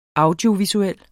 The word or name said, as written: audiovisuel